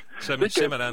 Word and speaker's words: Shyamalan: Shyamalan, là. – Shyamalan.